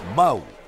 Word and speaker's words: Mahou: Mau. La H no se pronuncia.